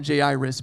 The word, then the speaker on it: Jairus: Name was, we're going to call him Jairus because that phonetically just sounds correct.